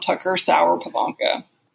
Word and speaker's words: Tucker Sauer-Pivonka: Tucker Sauer-Pivonka.